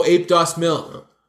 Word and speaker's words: Ape Dos Mil: Oh, Ape Dos Mil.